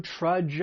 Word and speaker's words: trudge: That DG has that J sound, similar to the word judge. These are rhyming words. Trudge, judge.